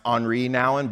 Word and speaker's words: Henri Nouwen: It's actually probably pronounced Henri Nouwen, but I'm from Mississippi, so we're sticking with Henry.